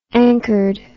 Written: anchored

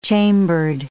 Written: chambered

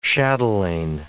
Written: chatelain

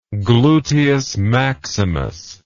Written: gluteus maximus

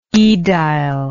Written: aedilitian

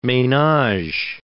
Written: menage

